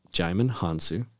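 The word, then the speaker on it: Djimon Hounsou: Jaimin Hansu.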